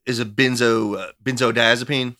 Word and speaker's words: benzodiazepine: I mean, Klonopin is a benzodiazepine.